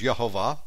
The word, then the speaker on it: Jehovah: You can read it as Jehovah in English. The actual pronunciation is Yehovah, spelled with a Y, if you want to spell it out in Hebrew.